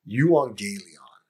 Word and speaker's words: euangelion: And in Greek, the word gospel is pronounced euangelion.